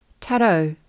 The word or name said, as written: tarau